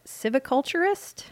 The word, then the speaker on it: silviculturist: So technically, your last position was a silviculturist.